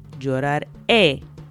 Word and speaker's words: lloraré: But if you wanted to say I will cry, you would say lloraré, lloraré. So we're adding the letter E with an accent over it. Again, so the stress is on the last syllable, which again is very important.